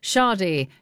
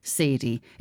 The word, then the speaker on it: Sade: And one of them was Sade, not pronounced Sadie.